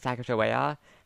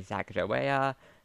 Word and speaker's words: Sacagawea: So, it's funny, so, because the pronunciation of Sacagawea has actually been debated throughout history. Some people say Sacagawea, some people say it's Sacagawea, and some historians also say it's actually Sacago, like Sacagawea, with a hard C.